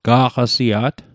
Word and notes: ga'qasia't: Mi'gmaq

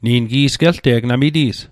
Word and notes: gelte'g: Mi'gmaq

